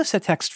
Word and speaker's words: Setext: The name of the format, I'm not sure how to pronounce it, because in the internet, when this came out, we were all reading things and not speaking them, so no one knows how it's actually pronounced. Anyway, Setext. S-E-T-E-X-T. Setext? Setext? – Setext. – Setext. – Yep.